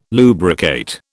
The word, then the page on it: lubricate: British English